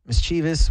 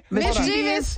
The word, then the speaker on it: mischievous: Mischievous. What is, Mischievous, you said it wrong. – What did he just say? – Mischievous. – Where were you? – Mischievous. You said mischievous.